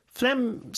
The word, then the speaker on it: phlegm: Phlegm 스펠링이 PH예요. – 네. – PH. LEGM인데 어떻게 읽냐면 phlegm 이렇게 읽죠? phlegm. 기침은 cough니까.